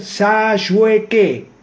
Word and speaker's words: Sayueque: Walter Sayueque. Así suena y así se escribe.